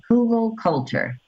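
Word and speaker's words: Hugelkultur: H-U-G-E-L. It's called Hugel Culture. H-U-G-E-L-K-U-L-T-U-R-E. So I just put up two videos on my channel explaining it. And what it is we've made a raised bed. Technically, Hugel Culture is they dig a ditch, they throw in some logs, cover it over, and then plant on it.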